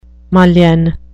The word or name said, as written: mullion